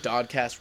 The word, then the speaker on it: DOD-Cast: it's just DOD-Cast rhymes with podcast, so it made sense.